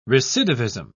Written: recidivism

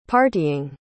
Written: partying

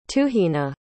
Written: tuhina

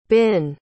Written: binh

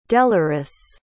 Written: deloris

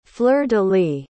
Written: fleur-de-lis

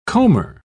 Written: comber

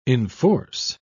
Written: enforce

